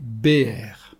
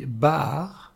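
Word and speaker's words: Béer (באר): En hébreu, le puits se dit « Béer ». C'est un bet, un aleph et un resh. Cela signifie le puits, la source. Prononcé « Baar », ce sont les mêmes lettres, bet, aleph, resh, mais vocalisé différemment, « Baar ».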